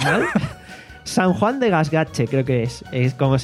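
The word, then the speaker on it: Gaztelugatxe: Gasgache. – Vale, pues eso. Vale. – El TX es más o menos una... – Es la Che.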